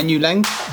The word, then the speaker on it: Nu Leng: So it's Taiki Nu and Nu Light. It used to be two guys and now it's one. So it used to be Taiki and Nu Light and then now it's Taiki Nu Light.